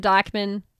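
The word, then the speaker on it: Dijkman: So it's at Megan Dykman.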